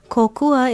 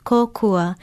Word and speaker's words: kōkua: Kōkua is one of the Hawaiian words most frequently used in English conversation, but it's often mispronounced. Write it down and put a kahako or stress mark over the first vowel, then say it aloud, kōkua. It's kōkua, and it means help, aid, assistance, relief, assistant, helper, and more.